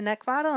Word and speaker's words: Neckvatal: You can also locate me and follow me over on YouTube and LinkedIn by just typing in my name, which is Brenda Neckvatal, and the last name is spelled N E C K, like the thing you want to choke. V as in Victor, A T A L.